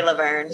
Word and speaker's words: Laverne: For those of you guys who don't know, Zoe Laverne, I think, do you know how to, is that how you pronounce it, Laverne or? – Zoe Laverne. Yeah, Laverne is pregnant, quote unquote, like we don't know.